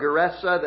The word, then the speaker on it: Gerasa: Mark and Luke get a little more specific and tell us the particular area of that country, the particular village, Gergesa or Gerasa, the slightly different pronunciations, but they're the same town, they're the same place.